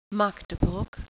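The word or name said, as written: Magdeburg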